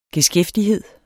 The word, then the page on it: geskæftighed: [ geˈsgεfdiˌheðˀ ]